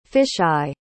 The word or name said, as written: fish-eye